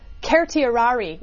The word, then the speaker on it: certiorari: Will they issue a writ of certiorari, or the actual correct Latin pronunciation, I am told by Latin professors, is certiorari, which nobody in the history of lawyers has ever used in their life.